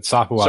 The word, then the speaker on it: Sahuagin: That's how I've always pronounced it. Sahuagin.